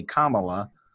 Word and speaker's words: Kamala: So I think the easiest way to remember it is definitely, I think we've talked about it before, is like the like a comma, like in English class, you know, when you put a comma into something. So if you say Kamala, that's, you're saying it right.